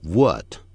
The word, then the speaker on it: wood: The house is made of wood. Wood 那是跟那个 book 发音是一样， good 发音完全一样。